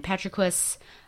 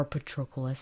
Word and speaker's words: Patroclus: And then Patroclus, or Patroclus, some, you know, different pronunciations there, enters the fray and he's killed.